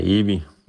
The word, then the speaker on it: EWII: Vil du ikke lige starte med at udtale, siger det rigtigt, EWI? EWI? – Ja, EWI. – EWI bare. Det staves E-W-I-I.